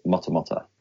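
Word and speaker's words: Matamata: So it's Mutamata, but the actual pronunciation from a Māori point of view is Mutamata.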